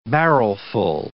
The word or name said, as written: barrelful